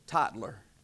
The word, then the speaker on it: Tytler: I actually listened to the pronunciation of that name online because I was pronouncing it different. Tytler, T-Y-T-L-E-R.